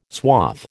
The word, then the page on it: swath: American English